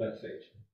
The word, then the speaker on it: Bethphage: I call it Bethphage. Okay, there's different pronunciation.